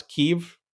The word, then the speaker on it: Kiev: You're not massing them all on and let me pronounce Kiev. – Kiev. – Thank you.